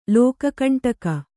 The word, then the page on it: ಲೋಕಕಂಟಕ: Kannada